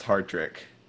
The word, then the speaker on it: Hartrick: You should know my last name is pronounced Hartrick.